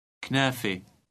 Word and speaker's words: كنافة: Read number 12 and note the final silent T, pronounced E. – كنافي.